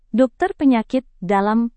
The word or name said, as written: dokter penyakit dalam